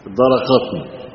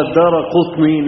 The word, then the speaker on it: الدارقطني: هو نطقها هكذا الدارقطني، تمام. وإنما هو الدارقطني نسبة إلى دار القطن، وإحنا عندنا في مصر بنزرع القطن طويل التيلة، ها قطننا طويل التيلة على حسب الضبط بتاع صاحبنا، نسبة إلى دار القطن.